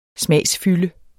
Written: smagsfylde

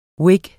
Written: whig

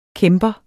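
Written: KÆMPERNES

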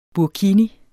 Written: burkini

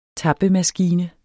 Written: tappemaskine

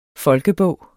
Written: folkebog

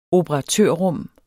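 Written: OPERATØRRUM

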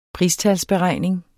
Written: pristalsberegning

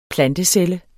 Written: plantecelle

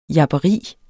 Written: japperi

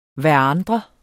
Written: hverandre

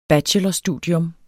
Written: bachelorstudium

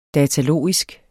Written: datalogisk

